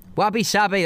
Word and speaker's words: wabi-sabi: Wabi sabi, that's it. There Wabi we go. Wabi sabi. – It rhymes.